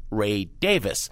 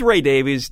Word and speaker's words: Davies: he would like people now to pronounce his name Ray Davis. – I'm sorry, Jim. We can't go there. He's Ray Davies to us. He's always going to be Ray Davies. Ray, it's a little too late to change the pronunciation of your last name.